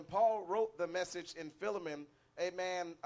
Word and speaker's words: Philemon: The prophet was referring to a scripture when he preached this message Philemon, I'm not sure how you pronounce it. Philemon, all right, thank you.